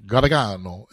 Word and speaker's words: Gargano: Gargano. – It's close enough. – It's supposed to be, like, the old world says it, like, Gargano. Gargano is how you say it. In Italy.